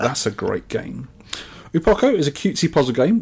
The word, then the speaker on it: Uo Poko: This is Upoko, or Lakpoko. Lakpoko. I don't know how to pronounce it. It's U-O-Poko. So we call it Upoko.